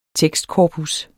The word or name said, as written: tekstkorpus